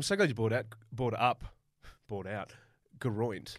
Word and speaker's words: Geraint: Well, you know, the other really prominent one, Geraint Thomas, we call him Geraint. Technically, it's like Geroint. – It's Geroint.